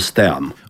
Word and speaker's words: STEM: Jā, nu tad šan un tad es dzirdu, ka tiek teikt STEAM. – Jā. – Nu, tātad tiek pārnest angļu izrunu uz latviešu valodu. Nu, tas nebūtu vajadzīgs. Sakam STEM.